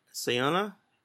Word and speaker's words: Sana'a: Sana'a.